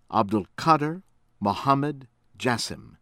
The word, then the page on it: Abd Al-Qadar Mohammed Jassem: American English